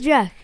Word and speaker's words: Juszczyk: His name is spelled like Juszczyk Kicks or something. – It's Juszczyk.